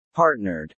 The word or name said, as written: partnered